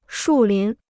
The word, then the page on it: 树林: shù lín